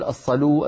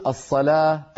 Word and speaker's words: الصلاة: الصلاة ما في عربي يقول الصلو الزكو الحيو، كلنا نقول الصلاة الزكاة الحياة ولكنها تكتب بالواو.